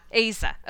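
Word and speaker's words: Eiza: So then Ansel Elgort and, oh my gosh, I'm going to butcher her name. Aiza Gonzalez? – Oh yeah. Aiza.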